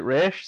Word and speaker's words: Ra's: For me, Denny O'Neill always pronounced it Ra'sh, so everyone else should have done because he was the guy who created him.